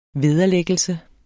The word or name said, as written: vederlæggelse